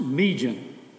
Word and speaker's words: Meegian: Her first name is spelled M-E-E-G-I-A-N, pronounced Meejan. Her last name is G-O-S-S-A-R-D.